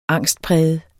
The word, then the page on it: angstpræget: [ -ˌpʁεjəð ]; [ -ˌpʁεˀjəð ]